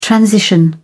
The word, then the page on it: transition: British English